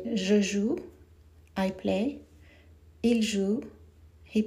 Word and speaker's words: joue: In French, khad, the singular, one cheek, is joue, J-O-U-E, J-O-U-E. In the plural, khudud, is the same pronunciation, joue, but it has an S at the end, J-O-U-E-S. The English spelling is J-O-U-E-S.